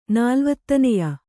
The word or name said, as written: ನಾಲ್ವತ್ತನೆಯ